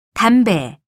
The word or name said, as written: 담배